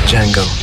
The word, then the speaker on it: Django: Django. D-J-A-N-G-O. The D is silent.